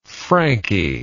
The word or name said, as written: Frankie